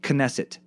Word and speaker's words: Knesset: Also, Knesset is pronounced Knesset. The K is not silent.